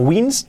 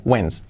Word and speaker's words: wins: No wins, la I como una E, wins.